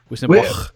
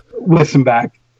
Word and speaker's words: Wissenbach: Wissenbach? How do you say it? What's the right way? – Wissenbach. Wissenbach. – Wissenbach. Okay. – Any way you want to. We just roll with it.